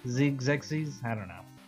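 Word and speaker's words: Xexyz: So, according to the pronunciation on Wikipedia, it is pronounced Zexies. – Zexies.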